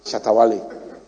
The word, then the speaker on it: Shatta Wale: You see, I pronounce it Shantawale instead of Shatta Wale. My children will be laughing at me that you have been saying Shantawale. They feel that I'm disgracing them. I'm not pronouncing the name of a star in Ghana well. Shanta. Okay. Okay. Okay, we shatter. Eh? I say, I shatter. Eh? Not shanta. Okay, so Shatta Wale.